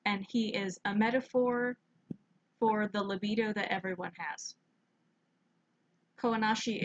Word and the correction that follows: Kaonashi: isn't Kawanashi. I'm pronouncing it wrong, like the dude that I listen to. Kaonashi is inside of everybody.